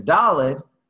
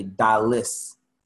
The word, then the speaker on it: Daled: And then the Daled, although most Ashkenazim say Daled has a duh sound at the end, it's actually dalis, which is related to the word impoverished. So the idea is that goimel or gimel is the one who bestows on the dalis, the one who is impoverished.